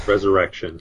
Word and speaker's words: Resurrxion: Resurrection. – Yeah, to say it like it's supposed to look. – Well, Resurrection, but people need to know that it's spelled RESURRXION.